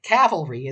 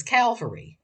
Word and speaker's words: cavalry: mispronounce the word cavalry as cavalry. Cavalry comes from a Latin word for horse,